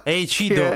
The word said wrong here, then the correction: Eiichiro: Eiichiro. Esatto, si dice, allora, in giapponese si pronuncia Eiichiro.